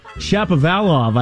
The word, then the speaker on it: Shapovalov: Dennis Sapovalva? What's his last name? – It's not Sapovalva, I'll tell you that. – the tennis player. – Dennis Shapovalov, I believe, is how you pronounce it.